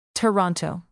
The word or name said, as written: toronto